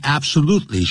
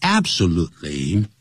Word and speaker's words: absolutely: absolutely sure, 不要念成 absolutely, 要念成 absolutely, 这个重音在第三音节、美国人在念这个说法是比较多的。